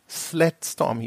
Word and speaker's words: SledStorm: Okay, dann ich sag mal Sledstorm. – Wie schreibt man das denn? – So wie Schlitten auf Englisch und Storm. – Okay. Sled. Nein? Slay oder Slays? – Sled. Sledstorm hieß es.